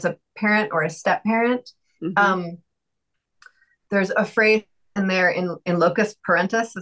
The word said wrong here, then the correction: in loco parentis: Is that, am I saying that right, Robin? – Loco parentis. – In loco parentis,